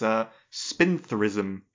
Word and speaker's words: spintherism: It's spintherism. – Spintherism. – Yes, it's spelt S-P-I-N-T-H-E-R-I-S-M.